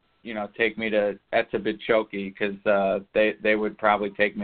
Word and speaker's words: Etobicoke: Etobicoke, but you were very close. – Oh, okay. – Very impressive. Yeah, Etobicoke. It's Etobicoke. – Okay. – The K is silent.